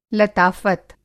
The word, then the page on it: लताफ़त: Hindi